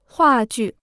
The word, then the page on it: 话剧: huà jù